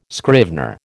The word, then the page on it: scrivener: American English